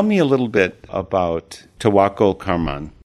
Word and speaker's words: Tawakkol Karman: How would you pronounce it correctly? – Yeah, it's Tawakkol Karman. – Tawakkol Karman.